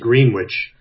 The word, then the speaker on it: Greenwich: It's spelled exactly like Greenwich, Connecticut, but it's Greenwich, New York, pronounced Greenwich.